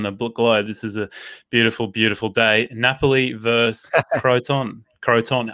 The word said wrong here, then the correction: Crotone: I don't know how to pronounce it. – Crotone.